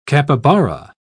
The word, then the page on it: capybara: British English